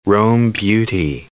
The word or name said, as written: Rome Beauty